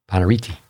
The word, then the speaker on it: Panariti: Panariti. – You changed it? I've said it right 10 out of 10 times. Now I've got the mic in front of me. Lorenzo Panariti. – There you go. – Got to sing it.